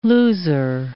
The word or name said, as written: loser